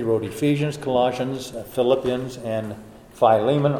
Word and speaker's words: Philemon: Colossians and Ephesians and Philippians, and we call it Philemon, but it's actually pronounced Philemon.